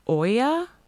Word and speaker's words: olla: I think it's called, it's spelled Ola, but I'm pretty sure that it's pronounced Oya.